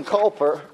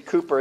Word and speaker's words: Cowper: The hymn writer, William Cowper, or Cooper, however you pronounce it, I think it's supposed to be Cooper, even though it's spelled Cowper, he said, this is the first ground of comfort, a meditation of the righteousness of God's nature.